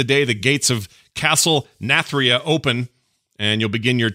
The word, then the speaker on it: Nathria: Castle Nathria. Am I saying it right? – Nathria. – Nathria.